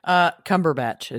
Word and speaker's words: Cumberbatch: Well, Cumberbatch is how you pronounce his last name.